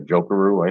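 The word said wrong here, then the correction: Jokiharju: I think. – Jokiharu, there you go.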